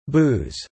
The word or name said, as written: booze